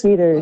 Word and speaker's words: Deters: Jacob Deters, is that correct? Did I pronounce Deters. – That correctly? Deters? – Deters.